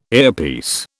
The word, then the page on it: earpiece: British English